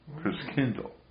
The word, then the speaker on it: Christkindl: Now, I want to also mention about the German pronunciation of Christchild was Christkindl, which where we get the word Kris Kringle from.